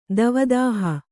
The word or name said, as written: ದವದಾಹ